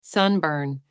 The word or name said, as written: sunburn